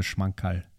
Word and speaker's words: Schmankerl: A Schmankerl. You don't need more of a Schmankerl.